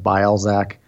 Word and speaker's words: Bialczak: It's like a crazy Polish last name. I think it's pronounced Bialczak. I pronounce it Bialczak. And in high school, it was pronounced very differently.